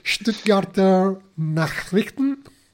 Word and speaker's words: Stuttgarter Nachrichten: Stuttgarter Nachrichten.